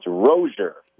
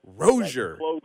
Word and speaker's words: Rozier: How is it he likes it to be said? – It's Rozier. – Rozier. – Like closure, which is exactly what we got in this Miami quarterback battle this week.